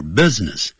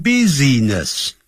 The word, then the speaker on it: business: 这跟你没有关系。None, N-O-N-E. None of your business. B-U-S-I-N-E-S-S.那个不要念成 business, 叫 business, 那个 I 是不发音的 business。好啦，他就是不听。The only time she gets excited,